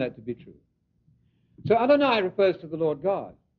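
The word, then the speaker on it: Adonai (אֲדֹנָי): So El Shaddai rhymes with Adonai.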